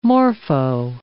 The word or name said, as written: morpho